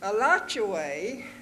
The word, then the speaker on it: Alachua: But the settlers called it Alachuay. And that's what their descendants call it still. Some of the young ones used to get even with the people that didn't know how to pronounce the name. If anybody came along and asked the way to Alachuay, they told them exactly where to go.